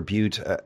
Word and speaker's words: Bute: I never know whether it's pronounced. I've read it both ways, that it's pronounced Bute or Bute. How do you say it? – Bute in English.